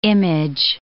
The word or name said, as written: image